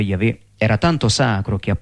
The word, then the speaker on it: Yahweh: Secondo gli esperti, esso si pronuncia Yahweh, nome che è stato italianizzato in Geova.